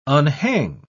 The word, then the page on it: unhang: British English